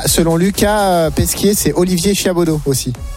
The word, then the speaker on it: Chiabodo: Quand tu écris Chiabodo, c'est H-I-A-B-O-D-O. – Non, c'est Chiabodo. – Comme ça se prononce.